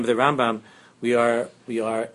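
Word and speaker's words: Rambam (רמבם): Since in Pnimius, the Rambam is Chachma, Chachma is the right side, the Pnimius of Malchus is Chachma, the right side, therefore we say the name Rambam, Rambam. Rambam, Mil-El, with the Dogesh, the emphasis on the right side of the name, because he comes from the right side.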